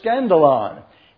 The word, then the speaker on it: skandalon: It is the word S-K-A-N-D-A-L-O-N. And you can just pronounce that word. It's the word scandalon.